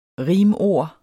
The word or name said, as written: rimord